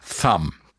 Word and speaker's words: Thumb: Bei Wörtern, die Buchstabenkombination MB enthalten, vor allem am Ende, wird das B nicht ausgesprochen. Der Nirvana-Song heißt also Dumb, der Stones-Song Under My Thumb.